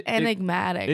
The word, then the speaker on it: enigmatic: Yeah, I think it's enigmatic. – Enigmatic? – No. – Oh, wow. – You're saying ig. It's enigmatic.